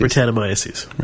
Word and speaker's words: Brettanomyces: How do you uh, pronounce that? – I read that in my. Isn't it magazines, Brettanomyces. Brettanomyces.